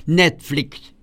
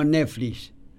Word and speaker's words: Netflix: A diferencia de quedarse, como dice Moria, Moria lo pronuncia como se debe pronunciar, Netflix, no Netflix, Netflix.